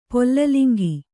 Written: ಪೊಲ್ಲಲಿಂಗಿ

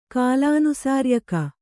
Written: ಕಾಲಾನುಸಾರ್ಯಕ

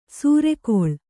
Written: ಸೂರೆಗೊಳ್

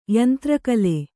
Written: ಯಂತ್ರಕಲೆ

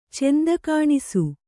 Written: ಚೆಂದಕಾಣಿಸು

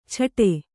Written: ಛಟೆ

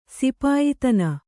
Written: ಸಿಪಾಯಿತನ

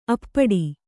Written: ಅಪ್ಪಟೆ

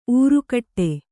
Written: ಊರೆಗೊಡು

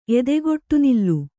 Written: ಎದೆಗೊಟ್ಟುನಿಲ್ಲು

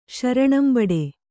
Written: ಶರಣಂಬಡೆ

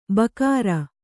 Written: ಬಖೈರು

